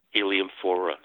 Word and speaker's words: Heliamphora: I don't know if I've said that in the same way that you would pronounce it, but... – Yes, Heliamphora, yeah.